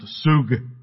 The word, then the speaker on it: sug: The Hebrew word is pronounced sug. Sug. I asked my rabbi friend how to pronounce that, and that's what he said. Sug.